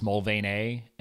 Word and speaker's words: Mulvaney: Like here, everybody calls it Mulvaney. Over there, it's pronounced Mulvaney, and there's no Y on the end.